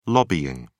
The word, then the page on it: lobbying: British English